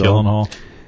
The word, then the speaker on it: Gyllenhaal: And then Jake Gyllenhaal, I don't know how to say his name, Jake Gyllenhaal. – Gyllenhaal.